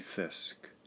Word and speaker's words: fisc: That is my key to learning trust, to choose to take a risk, my essential path to intimacy and our Lord's mighty fisk. Turned out that this word I needed to rhyme with risk, F-I-S-C, is a state or royal treasury.